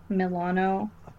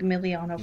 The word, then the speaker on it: Milano: We've talked, one of our faves, Alyssa Milano. I always say her name like Miliano for some yeah.